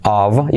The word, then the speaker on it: of: Esa F, la F cuando va sola se pronuncia con V. Of. Of. ¿Podrían haber puesto una V? Pues no, una F.